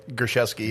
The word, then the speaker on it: Gerszewski: Gerszewski, yep.